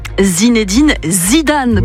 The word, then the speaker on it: Zinedine Zidane: Zinedine Zidane, oui. Pardon, je l'ai un petit peu écorché et on ne peut pas faire ça avec Zizou, c'est pas possible.